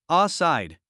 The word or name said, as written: outside